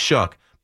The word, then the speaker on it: Shough: Remember the name, Tyler Shuck, but pronounced like pronounced Shuck, but spelled show, like though, but S-H-O-U-G-H. Tyler Show out of Louisville.